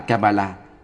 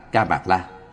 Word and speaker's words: Kamala: Bệnh Kamala, phiên âm từ Phạn ngữ là Kamala, cũng đọc là Ca Mạc La,